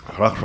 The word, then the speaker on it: Rauch: But right now I'm drinking a Rausch beer. A Rausch Weizen? How do you say it? – Noch ein Bier bitte. – I spoke ein bisschen Deutsch. Rauch Weizen.